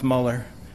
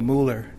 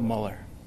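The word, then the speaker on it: Müller: My name is still pronounced Muller. He's turned me into a Mueller. He sees those, M-U, and he sees the two dots. And it is true, if I pronounce it that way, it would be Mueller. But it's still Muller.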